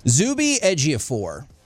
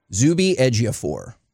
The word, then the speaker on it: Zuby Ejiofor: Zuby Ejiofor. – And spell it. – Z-U-B-Y. – Z-U-B-Y. – E-J-I-O-F-O-R. – Say it again. – Zuby Ejiofor.